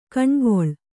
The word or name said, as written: ಕಣೆಗೊಳ್